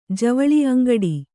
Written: ಅಂಗಾಡಿ

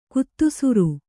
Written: ಗೋಧಿಸರ

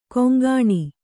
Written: ಕೊಂಕಣಿ

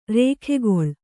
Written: ರೇಖೆಗೊಳ್

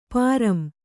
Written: ಪಾರಂ

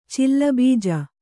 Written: ಚಿಲ್ಲಬೀಜ

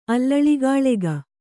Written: ಅಲ್ಲಳಿಗಾಳೆಗ

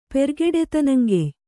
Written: ಪೆರ್ಗಡೆತನಂಗೆಯ್

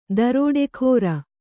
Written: ದರೋಡೆಖೋರ

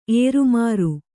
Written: ಏರುಮಾರು

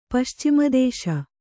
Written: ಪಶ್ಚಿಮದೇಶ

